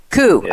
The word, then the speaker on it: Khoo: Am I pronouncing your name right? Khoo or Khoo? How do we pronounce it? – Khoo.